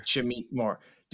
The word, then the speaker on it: Shameik Moore: Shameik Moore.